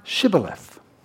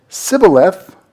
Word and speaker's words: Shibboleth: If he said, "No," then they would say to him, "Say now, Shibboleth." But if he said, "Sibboleth," for he could not pronounce it correctly, then they seized him and slew him at the fords of the Jordan.